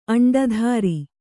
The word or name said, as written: ಅಂಡಧಾರಿ